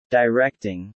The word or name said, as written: directing